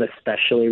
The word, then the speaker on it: especially: Technically, the correct pronunciation is with the consonant followed by the consonant, sp. People have incorporated this KS pronunciation instead of the SP pronunciation. It's a well-known process, that said, the actual pronunciation of especially remains with the SP sound.